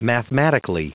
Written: mathematically

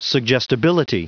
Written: suggestibility

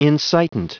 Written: incitant

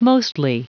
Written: mostly

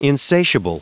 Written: insatiable

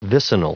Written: vicinal

